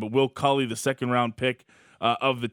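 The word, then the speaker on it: Cuylle: Well, so to speak about – it's Cooley is how he pronounced it.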